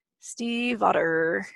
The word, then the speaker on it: Stiefvater: Stiefvater. – Steve, like the name. That's all I remember. Otter, like the animal, Stiefvater.